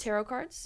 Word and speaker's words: tarot: Tarot cards. – Yeah. – So the T silent.